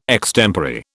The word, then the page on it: extempore: British English